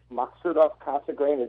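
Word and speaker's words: Maksutov-Cassegrain: It's a simple 4-inch Maksutov Cassegrain.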